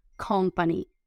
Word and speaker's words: company: Termina en Y, pero la Y se pronuncia como I latina. Company, company. Otra vez una P, entonces otra vez fuerte. Company, company. Por favor, pongan la tilde donde toca que es en el principio.